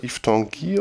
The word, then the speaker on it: Yves Tanguy: Yves, so spricht man den aus, oder? Yves Tanguy oder so? – Ja.